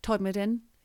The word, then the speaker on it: Todmorden: Todmorden, that's how they say it in West Yorkshire, is known as UFO Alley.